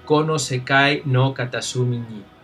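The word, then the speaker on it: Kono Sekai no Katasumi ni (この世界の片隅に): como se pronunciaría en japonés Kono Sekai no Katasumi ni.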